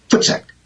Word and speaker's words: voetsek: And it's spelled V-O-E-T-S-E-K, but it's pronounced like your feet, like footsack? – Yes, because the V in Afrikaans is pronounced as an F. – Oh, okay. – So, footsack. And double O in English is O-E in Afrikaans. So it is footsack.